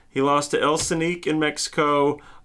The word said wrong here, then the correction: El Canek: You mean El Canek? – El Canek, yeah. I'm trying too hard with my pronunciations.